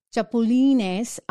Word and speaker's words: chapulines: And this other one, chipulines, I don't know how to say it, otherwise known as grasshoppers. There you go, grasshoppers. It's a Spanish name. So chapulines, they're eaten in Mexico.